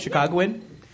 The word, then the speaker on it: Chicagoan: Ma'am, you are you're a Chicagoan? – Yes. – Am I saying that right? Chicagoan? Yes.